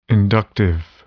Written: inductive

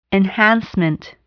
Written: enhancement

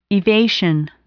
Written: evasion

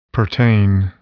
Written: pertain

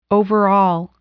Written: overall

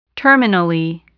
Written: terminally

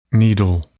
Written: needle

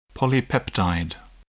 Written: polypeptide

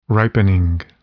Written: ripening

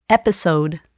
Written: episode

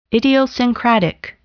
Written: idiosyncratic

